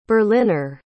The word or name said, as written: berliner